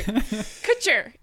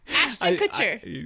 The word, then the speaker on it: Kutcher: And you said that so weird. – I know. Kutcher? I'm like, am I saying his name right? – Kutcher. Ashton Kutcher.